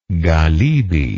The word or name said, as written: galibi